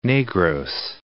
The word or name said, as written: Negros